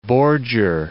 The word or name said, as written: bordure